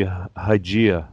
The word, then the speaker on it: Hygieia: Y-Y-I-E-I-A? – Oh, that's supposed to be Hygieia. Like hygiene.